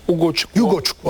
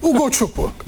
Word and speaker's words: Ugochukwu: Yani Dani Olmo, Foden finalde Ogocukwu olunca, doğru mu telaffuz ettim? – Ugochukwu hatta. – Leslie diye gitsin ya. – Herkes öyle diyor zaten. – Leslie. – Leslie diye. – Doğru. – Ugochukwu.